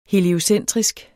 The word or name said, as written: heliocentrisk